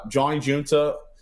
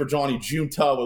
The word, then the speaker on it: Johnny Giunta: Thanks for having me on Johnny Giunta on Twitter. And by the way, people think my last name is spelled with two A's. That's just Twitter not giving me the domain for Johnny Giunta with one A.